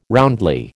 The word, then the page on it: roundly: American English